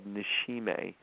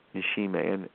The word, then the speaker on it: nishime: I'll spell it, it's actually called nishime. It's N as in Nancy, I-S-H-I-M-E, nishime.